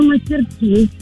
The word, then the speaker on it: мастерски: Ну, вообще, я говорю мастерски на последнее «и».